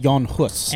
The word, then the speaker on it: Jan Hus: But it's pronounced Jan Hus.